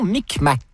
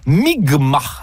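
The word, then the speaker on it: Mi'kmaq: Où Jacques Cartier et son équipage vont être accueillis par des membres de la nation Micmac, qui se prononce en fait Mi'kma, dans ce qui sera le premier contact.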